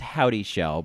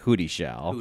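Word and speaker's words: Houdyshell: Jane, it's apparently pronounced Howdy Shell, but I like to pronounce it Hootie Shell.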